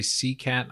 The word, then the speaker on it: Seacat: Is it terrible that when you say sea cat, I envision a tiger riding on a shark? – It's exactly how it's spelled as well.